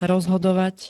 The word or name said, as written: rozhodovať